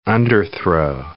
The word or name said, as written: underthrow